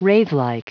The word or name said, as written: wraithlike